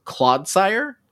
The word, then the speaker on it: Clodsire: And it evolves into Clodsire. Is that how you say it? – Yep.